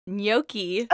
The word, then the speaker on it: gnocchi: So apparently you know how to make gnocchi.